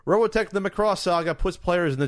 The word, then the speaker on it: Macross: Macross.